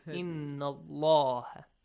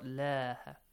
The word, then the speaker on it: Allah: The word Allah Almighty, we have told you a lot, okay, and I will repeat that the word Allah, whenever there is a fatha sound before the word Allah or a dhamma sound, the word Allah, the lam, the little lam of the word Allah, will be pronounced heavily. Inna Allaha. Not laha. Inna Allaha.